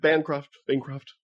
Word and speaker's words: Bancroft: It's Bancroft, but like, I'm not really picky. – Bancroft.